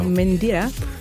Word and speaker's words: Mentira: Mentira. – Mentira. – Mentira, exactamente. Ja, das Tee darf man betonen. Das Tee darf man fest betonen. – Mentira. – Muy bien, exactamente. – Gracias. – Also, Mentira, so stimmt's.